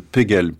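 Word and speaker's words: P'Gell: Alors là, il y a la plus célèbre et la plus remarquable, et la première, elle s'appelle Pegel, P'GEL, et c'est la prononciation américaine de Pigalle, le quartier de Paris.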